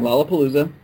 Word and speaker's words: Lollapalooza: I think that's how you pronounce it. – Yep, that is correct. Lollapalooza.